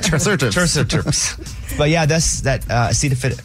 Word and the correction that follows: acetaminophen: How do you say it? – Now it's all jumbled together. – Acetaminophen. – Acetaminophen. Yeah.